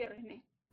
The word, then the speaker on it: Resnais: Primera y única peli que vi Resné. Resné. Me, mamá, mamá habla francés y me dijo que se pronuncia Resné.